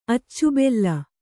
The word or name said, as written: ಅಚ್ಚುಬೆಲ್ಲ